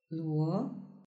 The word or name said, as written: luo